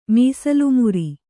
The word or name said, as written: ಮೀಸಲುಮುರಿ